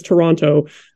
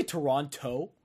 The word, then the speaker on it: Toronto: Did you just say Toronto? Did you just pronounce the T? – Did I?